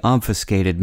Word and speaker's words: obfuscate: After the debate, each of the gubernatorial candidates complained to the press that his opponent had intentionally obfuscated many responses to the questions. The last syllable of the word obfuscate may sound like the skate in ice skate, but the two aren't spelled the same way.